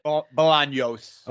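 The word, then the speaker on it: Bolaños: Bolanos, is that how you say it? – Bolanos.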